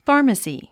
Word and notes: pharmacy: fάːrməsi; ファーマスィ

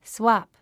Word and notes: swap: swάp; スワプ

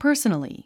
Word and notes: personally: pə’ː r s ə nəli; パァソナリィ

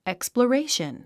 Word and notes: exploration: èkspləréiʃən; エクスプラレィション

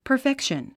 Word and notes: perfection: pə r fékʃən; パァフェクション

